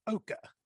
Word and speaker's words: Oaka: It's spelled O-A-K-A, but it's pronounced Oka. Am I saying that right? – You are. You are saying it correctly. And for some reason, a lot of people like to overthink it. And I don't know why. It's a two-syllable word.